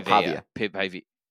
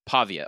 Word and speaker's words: Pavia: Is it Diego Pavia? – Diego Pavia. Pavia. Okay, yeah. I knew I was hitting the A wrong.